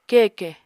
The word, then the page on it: keke: Apatani